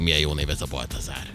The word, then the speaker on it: Baltazár: A névnapokra Rafael és Baltazár. – Ja, én Bazárt olvastam volna, között szóltál. – Baltazár.